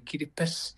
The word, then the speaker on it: Kiribati: Oh, it's just we don't have the S in our language, so we say Kiribati.